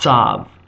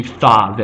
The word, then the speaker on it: Tav: because Tav is also pronounced Sav by the Ashkenazim, as opposed to Thav as it would be in original Hebrew.